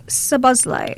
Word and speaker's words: Szoboszlai: Well, Dominic Szoboszlai.